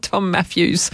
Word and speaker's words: Mafyoos: And no, I'm not mispronouncing Matthews. He's written M-A-F-Y-O-O-S as his last name. I don't know if that's what his, you know, what it says on the birth certificate, but Tom Matthews.